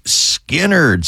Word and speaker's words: Skynyrd: It was on this day in 1973, Lynyrd Skynyrd released their debut album, pronounced Skynyrd.